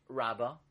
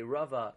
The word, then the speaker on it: Rava: So some of Farshim learn that the name Rava used to be pronounced Rabba, Resh Beis Aleph, which we pronounce today Rava, used to be pronounced Rabba, and the Remez is in the pronunciation, but not in the spelling.